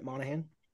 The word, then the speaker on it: Monaghan: Like, real quick before we talk about Michelle Monaghan, how do you pronounce her last name? – You said it right, Monaghan.